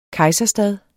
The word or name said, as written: kejserstad